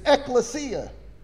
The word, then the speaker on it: ekklesia: Church, this New Testament word, if you look it up in the Strong's Concordance, this Greek word 1577, it means, the pronunciation in Greek is ekklesia.